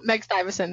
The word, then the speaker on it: Meg Stivison: No, Meg Stivison.